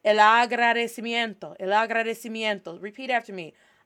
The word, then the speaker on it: agradecimiento: Agradecimiento.